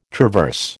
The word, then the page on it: traverse: American English